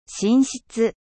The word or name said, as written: chambre